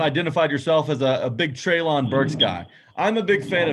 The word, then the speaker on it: Treylon Burks: Well, the thing about Treylon Burks, and you said it right, I don't care that he ran a 4.5 240 at the combine.